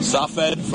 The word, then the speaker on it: Tzfat: Across the valley, we see the holy city of Tzfat. Safed. – Could you spell that, Tzfat? – Yeah. – Listener, want to look it up on a map. – Sure. T-Z-F-A-T, Tzfat.